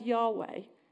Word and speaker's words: Yahweh: It was written as Yahweh. That's how we pronounce it. But it's, as you can see on the slide, it just says Y-H-W-H. We put the vowels in to make it easier to say.